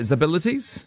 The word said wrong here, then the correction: X-Abilities: X-Abilities. – Named thank you. – Named after X-Men.